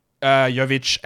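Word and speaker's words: Jović: And then what about Jovic and what about Hakez? Two blokes with the soft J's.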